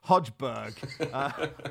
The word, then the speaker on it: Højbjerg: The pronunciation of Højbjerg, Højbjerg, as you know, is much discussed. The way you sounded it made it sound like the love child of Steve Hodge and Henning Berg, just Højbjerg.